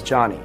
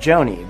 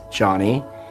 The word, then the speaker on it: Jony: For example, my name is Johnny, spelled J-O-N-Y. And it's normal for someone to pronounce my name like Joni.